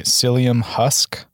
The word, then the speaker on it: psyllium husk: Psyllium husk? – Yeah, that's right.